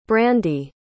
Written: brandee

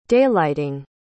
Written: daylighting

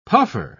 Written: puffer

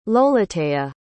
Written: lolotea

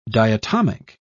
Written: diatomic

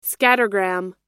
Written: scattergram

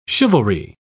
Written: chivalry

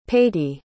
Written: paty

